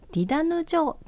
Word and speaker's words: 太陽の門: 来年の 2 月に、えー、南城市のシュガーホールで上演を予定している市民ミュージカル、太陽の門と書いてディダヌ城と読みます。